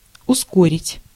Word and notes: ускорить: /ʊˈskorʲɪtʲ/

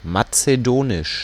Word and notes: macédonien: France; [ma.se.dɔ.njɛ̃]

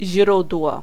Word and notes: źródło: [ˈʑrud̪wɔ]

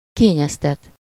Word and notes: choyer: France; /ʃwa.je/

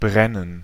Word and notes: write: /ɹaɪt/

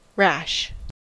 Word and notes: rash: /ræʃ/

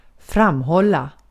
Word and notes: maintain: /meɪnˈteɪn/

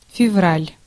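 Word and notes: helmikuu: /ˈhel.miˌkuː/; Finnish

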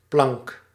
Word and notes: tablette: France; /ta.blɛt/

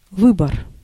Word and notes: выбор: /ˈvɨbər/

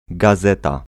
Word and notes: gazeta: [ɡaˈz̪ɛt̪a]

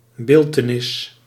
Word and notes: image: /ˈɪm.ɪdʒ/; [ˈɪm.ɪdʒ]

